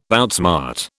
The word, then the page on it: outsmart: British English